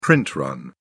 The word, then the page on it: print run: British English